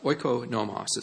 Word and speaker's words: oikonomos: Now, you will find in some versions it might be translated as manager, but this is the word oikonomos. It's spelled O-I-K-O-N-O-M-O-S.